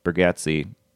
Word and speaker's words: Bargatze: I just saw the other day, Nate Bargatze.